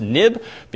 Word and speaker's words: XIB: Even though it's XIB, it's historically pronounced nib, being Next Step Interface Builder.